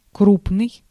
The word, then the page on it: крупный: /ˈkrupnɨj/